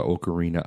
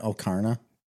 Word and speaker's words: Ocarina: Or number three could be Legend of Zelda Ocarina of Time. – Oh. Great. Isn't it Ocarina? – No. Ocarina. – It is? – Mm-hmm. – I think you're saying that wrong.